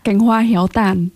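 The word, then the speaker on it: Cánh Hoa Héo Tàn: C'est Can Hoa Heo Tan. Bref, I mispronounced the last word. – Kang Hoa Heo Tan. – Yes, thank you.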